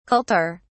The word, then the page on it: kultur: British English